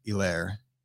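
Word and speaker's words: Helaire: It's the H is silent in Clyde Edwards Elair.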